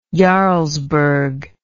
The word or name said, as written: jarlsberg